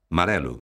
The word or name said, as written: marelo